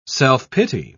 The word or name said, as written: self-pity